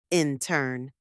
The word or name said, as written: intern